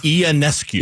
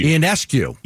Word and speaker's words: Ionescu: I'm looking forward to the that 3-point contest between Steph Curry and how do you pronounce her last name? Ionescu? Sabrina? – Ionescu. – Ionescu.